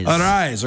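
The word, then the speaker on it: Arraez: Ariz, okay.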